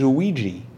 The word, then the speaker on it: Ouija: Because I always think of it as Ouija. And so, well, that's how you pronounce it.